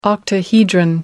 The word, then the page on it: octahedron: American English